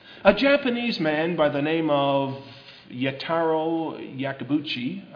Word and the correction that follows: Yamaguchi: Let me see, how was that? I mispronounced that. Yamaguchi.